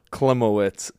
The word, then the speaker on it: Klimowicz: Klimowicz. – Klimowicz. – It's Polish. I've had it spelled wrong, pronounced wrong.